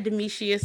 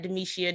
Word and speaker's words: Demishia: I am at Demetria Samuels on Instagram. I'm at Demetria W on Twitter, and that's spelled D-E-M-I-S-H-I-A.